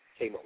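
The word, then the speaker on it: Kamose: Kamos.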